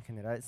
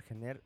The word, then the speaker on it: Gener: La R final en catalán central en general se muda en apellidos, por ejemplo, Sabater, Gener, se pronuncian Sabate y Gene.